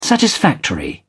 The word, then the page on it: satisfactory: British English